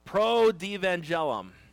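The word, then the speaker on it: protoevangelium: pro-devangelum. And how the T becomes a D, I don't know, but that's how it's pronounced.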